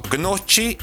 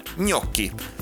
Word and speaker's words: gnocchi: A gnocsi nem gnocsi, és nem is gnocchi, hanem gnocchi. Na, tehát, hogyha nincs meg a szó valakinek, G-N-O-C-C-H-I, gnocchi, így írják, de gnocchinak ejtik, nem gnocsinak, és nem is gnocchinak, de majd egyszer ezt megtanuljuk.